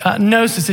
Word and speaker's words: gnosis: It comes from the root word gnosis. It's G-N-O-S-I-S. So the G is silent.